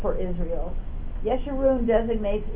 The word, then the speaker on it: Yeshurun: And he chose, my understanding is that this is pronounced Yeshurun, according to the, I don't know, anyway, Yeshurun, with a Y.